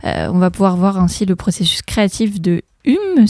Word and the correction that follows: Yumi: si je prononce bien, avec Lisa, Lisa et Zélie. – C'est Yumi, en fait.